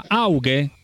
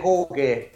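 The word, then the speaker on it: Hoedt: Preparati, però mi devi dire una cosa, prima di tutto come si pronuncia, la pronuncia corretta, Auge? – Allora, per i puristi sarebbe Hoge, però Auge.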